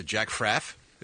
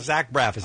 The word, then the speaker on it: Zach Braff: It's in the Jack Fraff, isn't that? – No, that's how Joan Rivers pronounced it. Zach Braff is actually the young man.